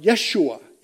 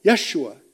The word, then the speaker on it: Yeshua: Uh, you might pronounce it more like Yeshua, Yeshua.